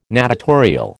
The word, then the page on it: natatorial: American English